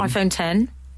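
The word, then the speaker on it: iPhone X: iPhone 10. – Is it called iPhone 10? – Yes. It's spelled X.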